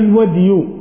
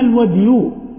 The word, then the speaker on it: الودي: ثم تحول إلى المسألة الثانية من غير المذي، وهي الودي، وقلنا يجوز نطق بالودي والودي. وأما الودي بدال مهملة، ابن العربي ومن رواه بذال معجمة فقد صحف.